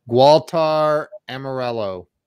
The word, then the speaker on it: Gualter Amarelo: Gualter Amarelo.